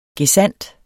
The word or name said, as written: gesandt